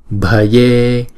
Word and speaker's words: bhaje: Chữ E là hai nhịp ha. Bhaje. Chữ B mà nếu đứng một mình thì chúng ta sẽ đọc là B. Chữ B và chữ H ghép chung lại chúng ta đọc là B, B.